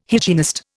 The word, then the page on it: hygienist: Indian